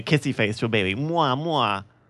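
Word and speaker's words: Oumuamua (ʻOumuamua): I think I was here when we to talk about the plan to look at this, but Oumuamu. – Are we I could chant too. Klaatu Vrata Niktu. – Hold on. I'm going to get the pronunciation here. Shut up. – Oumuamua. One more time? – Oumuamua.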